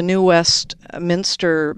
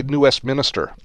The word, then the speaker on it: New Westminster: So let's do a search there for the New Westminster Master Transportation. – Hey, you said it properly. Most people say New Westminster. – They're not from here. – I'm from here, and I often say it.